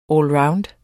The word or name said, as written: allround